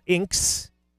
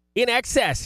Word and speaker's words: INXS: And the best way to pronounce this group, no, not INXS, not Inks. Bet you someone did as they were first coming out with this in 1985, but we know better. INXS, here's what you need on the late night class reunion.